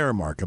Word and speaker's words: Aramark: Oh gosh, Aramark. Gosh, I hope I'm saying that right. – Yeah, Aramark. I believe that's correct.